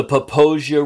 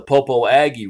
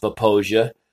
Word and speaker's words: Popo Agie: Now, I know I said Poposia. It's a Crow word that means grass river, but it is spelled P-O-P-O-A-G-I-E. So tourists usually call it the Popo Aggie River, to which the locals all know, hey, you're from out of town. It is actually pronounced Poposia, and it comes down out of the Wind Rivers, and it's a fairly fast-moving river.